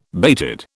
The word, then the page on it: bated: British English